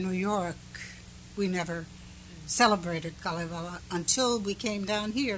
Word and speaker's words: Kalevala: Once a year, the Finnish community here has an evening for the Kalevala, as it is pronounced in Finnish.